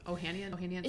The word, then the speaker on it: Ohanian: Alexis Ohanian, is that how you say his name? – Oh, yeah. Ohanian.